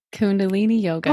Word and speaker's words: Kundalini: She has a BA in psychology, a second BA in recreation therapy, is certified in guided imagery, and is certified, help me, Kundalini? – Kundalini yoga.